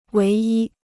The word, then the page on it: 唯一: wéi yī